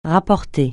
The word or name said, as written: rapporter